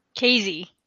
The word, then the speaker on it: Kayze: Did I pronounce that right? – Kayze. – Yeah. Kayze.